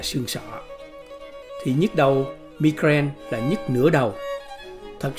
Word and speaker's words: migraine: Tiếng Anh đọc là migraine và tiếng Pháp đọc là migraine.